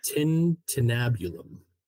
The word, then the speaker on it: tintinnabulum: It's a tintinnabulum.